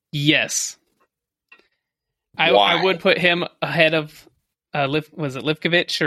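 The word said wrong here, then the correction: Livaković: I mean, I'm going to butcher it either way, but I think it's Livakovic. – Livakovic.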